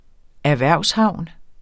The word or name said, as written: erhvervshavn